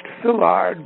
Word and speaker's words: Villard: The Northern Pacific was built by a German immigrant by the name of Henry Villard, which I think would have been pronounced Villard because V in German, like Volkswagen, in Germany is Volkswagen. So the V is pronounced as an F.